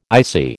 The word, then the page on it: ICI: American English